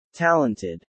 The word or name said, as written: talented